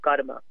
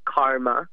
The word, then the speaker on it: karma: Another philosophical tenet that was central to the way I was raised was the law of karma. In this country, you all have heard of it. It's as karma. That's actually not how it is pronounced. It's pronounced karma.